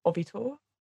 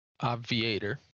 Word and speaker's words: Obviator: Now, I can't pronounce this one. I've been trying, but is it Ovitor? – Obviator.